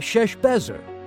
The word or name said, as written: Sheshbazzar